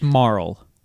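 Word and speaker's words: Marle: I looked up a pronunciation YouTube video, and it's Marl.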